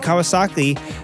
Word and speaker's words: Kawasaki: And Kawasaki, rhymes with broccoli, hopefully they have some old Z800s laying around that they can kind of revamp and call a 750.